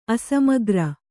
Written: ಅಸಮಗ್ರ

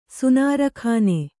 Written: ಸುನಾರಖಾನೆ

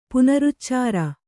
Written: ಪುನರುಚ್ಚಾರ

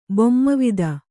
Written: ಬೊಮ್ಮವಿದ

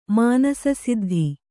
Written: ಮಾನಸಸಿದ್ಧಿ

